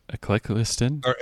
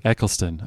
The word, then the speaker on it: Eccleston: Eccleston. – Eccleston.